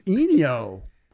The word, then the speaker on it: Ennio: It's Ennio. It's Eeny Meeny Minio.